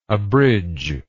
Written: abridger

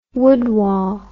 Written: woodwall

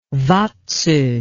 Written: vadso

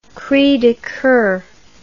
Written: cris de coeur

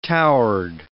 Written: towered

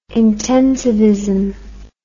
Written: intensivism

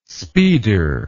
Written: speeder

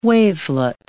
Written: wavelet

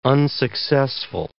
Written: unsuccessful

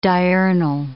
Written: diurnal

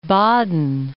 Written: Baden

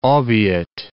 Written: obiit

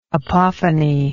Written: apophony

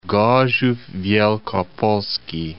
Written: Gorzow Wielkopolski

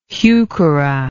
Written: heuchera

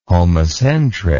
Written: homocentric

